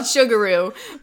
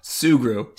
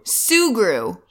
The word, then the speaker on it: Sugru: Okay, well, this week I am digging something I was convinced was called Sugaroo, but then we Googled it, and I guess it's called... – Sugru. – Sugru. – Yes, as it's spelled, S-U-G-R-U.